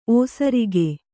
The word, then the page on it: ಓಸರಿಕೆ: ōsarike